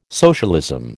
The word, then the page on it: socialism: American English